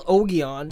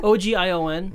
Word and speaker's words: Ogion: It's like Augarian or something like that. It's like an ah noise. – It's spelled Ogion. – Yeah. I saw that. I'm like, what? – Ogion.